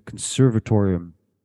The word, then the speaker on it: Conservatorium: It's also where they have it's so you said the name wrong. It's not the consortium; it's the Conservatorium Hotel.